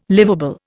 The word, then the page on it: livable: Indian